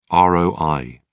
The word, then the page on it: roi: British English